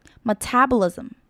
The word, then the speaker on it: metabolism: metabolism, metabolism. 再来结尾 S 跟 M 连起来念，发音会比较有 Z 的音，所以整个合起来念就是 metabolism Metabolism.